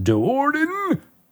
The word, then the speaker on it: Do'Urden: Go pick it up, listen to it, enjoy the ongoing adventures of Drizzt Do'Urden. You have to say it like that. Do'Urden!